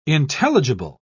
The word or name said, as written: intelligible